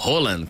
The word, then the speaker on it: Haaland: O Erling Haaland, ou Haaland, como falaram que é a pronúncia certa agora, ele tá fazendo aquela tática de pacificador, se fazendo ali meio de morto.